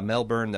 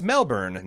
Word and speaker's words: Melbourne: I have some points about Melbourne that might help you out. First, it's pronounced Melbourne, not Melbourne, like most Americans pronounce it.